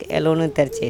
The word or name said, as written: elonetErcEk